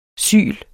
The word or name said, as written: syl